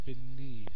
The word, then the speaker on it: beneath: And beneath, last one, beneath, so stress on the second syllable.